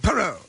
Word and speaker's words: Perou: I guess that's how you say his name. P-E-R-O-U. – Peru.